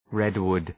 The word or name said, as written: redwood